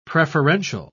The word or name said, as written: preferential